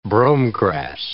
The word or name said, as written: bromegrass